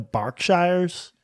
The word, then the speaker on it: Berkshire: This one I want the English pronunciation, okay? And you will be shot as Americans if you give me the American pronunciation. B-E-R-K-S-H-I-R-E. – Berkshire. – You can keep saying it, but it's going to carry on being Berkshire.